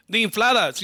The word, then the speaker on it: infladas: I'm not going to say this right, but the infladas, is that right? – The infladas. You said it beautiful.